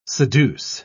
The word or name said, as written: seduce